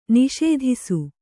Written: ನಿಷೇಧಿಸು

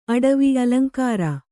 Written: ಅಟವಿ

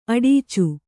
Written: ಅಡಚು

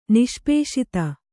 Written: ನಿಷ್ಪೇಷಿತ

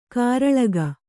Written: ಕೂರಲಗು

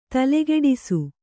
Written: ತಲೆಕೆಡಿಸು

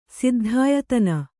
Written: ಸಿದ್ಧಾಯತನ

